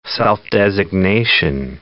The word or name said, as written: self-designation